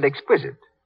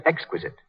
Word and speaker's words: exquisite: Mary Jane's mother said exquisite. She should have said exquisite.